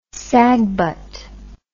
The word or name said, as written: sagbut